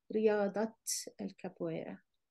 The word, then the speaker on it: رياضة: So just be careful here, since you have a ta marbuta here followed by a noun, you have to pronounce this as a ta. So it's riyada, but if you add al capoeira, then you have to say riyadat al capoeira.